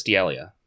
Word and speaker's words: D'Elia: Did I say his name wrong? – I have no idea who you're talking about. That's why I'm... – The comedian. – Oh, Chris D'Elia.